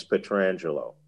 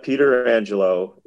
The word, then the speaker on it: Pietrangelo: I will say this, that if you ask Alex Petrangelo how he pronounces his name, he will tell you it's Alex Petrangelo. It's not Peter Angelo. It's not Petra Angelo or any other kind of bastardization of the name.